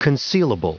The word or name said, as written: concealable